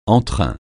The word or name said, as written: entrain